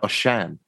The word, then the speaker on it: Osian: I saw Chris Grierson tweet a pronunciation. It was more like Osian.